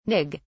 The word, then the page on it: nig: British English